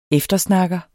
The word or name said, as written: eftersnakker